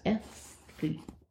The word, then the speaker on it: aesthete: It looks like athlete, but it's aesthete.